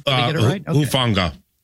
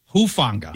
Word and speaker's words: Hufanga: Talanoa Hufanga. Did I say that right? You're good with pronunciations. – Hufanga. – Hufanga. Yeah. Thank you.